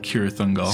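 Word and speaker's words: Cirith Ungol: Which one is it? – Cirithungol. – So in Tolkien, a C is always pronounced with a hard noise.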